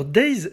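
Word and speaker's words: Daze: Alors Days, ça s'écrit D-A-Z-E, c'est un groupe danois qui euh, visuellement ressemble beaucoup au groupe Aqua.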